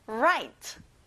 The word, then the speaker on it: write: 친구들, WR가 나오면 그냥 R 발음이 나와요. write.